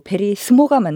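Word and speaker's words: verismo: 주변에 많은 관계로 베리스모가 맞나요? – 네, 스모가 맞습니다. – 스모가 맞나요? – 네, 사실 이탈리아의 S는 G로 발음된 경우가 극히 드뭅니다. 그냥 S로 보시면 됩니다.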